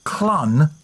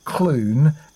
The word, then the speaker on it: Clun: Apparently, in the Shropshire episode, it's pronounced Clun, not Clune, and Reekin, not Reckon.